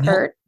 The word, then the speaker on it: Peart: Neil yeah, Peart.